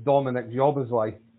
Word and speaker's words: Dominik Szoboszlai: So you just dropped the S. So it's Dominik Szoboszlai.